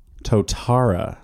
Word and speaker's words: Totara: Today we're talking about the Totara. – Yes. Well done.